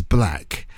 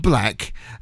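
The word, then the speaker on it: 6LACK: His name is Black, but it's a six. 6BLACK, yeah, but actually it's pronounced Black, and it's his birthday today, born in June 24th, 1992, yeah, so he's a mere child.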